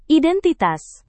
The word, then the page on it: identitas: Indonesian